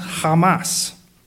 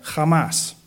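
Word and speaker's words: Hamas (חמס): That word violence in Hebrew is H2555, and it is pronounced Hamas. It is not spelled like that. But that's, and I'm not good at the H thing, but Hamas, which is a little ironic, isn't it?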